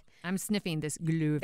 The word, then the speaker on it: Glühwein: Glühwein is mulled wine. – Okay. – And that's just how you say it in German.